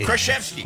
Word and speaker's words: Krasinski: Did I pronounce it wrong all the time? – Krasinski. – Krasinski. – Yeah, there you got it.